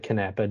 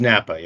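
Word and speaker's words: Knappa: Napa, or is it Canapa? Do we pronounce the K in this? – It's Napa, yeah.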